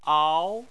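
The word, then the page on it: ao: tone 2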